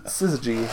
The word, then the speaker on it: Syzygy: Syzygy. – Synergy? Syzygy. – There's no R in there, even though I'm pronouncing it R.